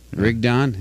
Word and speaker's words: Rigdon: How do you pronounce R-I-G-D-O-N? – I have to see it. – Rick Don? – Yeah. – Rick Don. His name is Rick Don Osmond Dees.